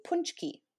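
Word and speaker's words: pączki: and I learned how to pronounce this word because I butcher it. it's actually punchki.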